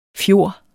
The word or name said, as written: fjor